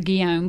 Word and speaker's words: Guillaume: Yeah, so the name of your label is just Ronaldo Guillaume, but Ronaldo, that's spelled G-E-U-M-M. – Yes. – So I couldn't figure out how to say that. – Yes. – But so it's Guillaume, like William in French, right? – Yes.